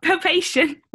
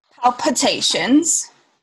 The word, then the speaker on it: palpitations: Pal- pal- palpation. What did I say? And here we are, another episode of Making Lemonade of how to pronounce things. palpitations.